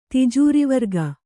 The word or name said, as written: ತಿಜೂರಿವರ್ಗ